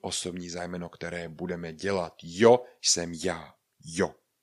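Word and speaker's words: yo: tak ho vyslovujeme jako J. Je to písmeno se tedy jo. Když uvidíme Y, O, jo. Jo.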